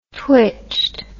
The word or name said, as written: twitched